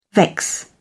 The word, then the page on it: vex: British English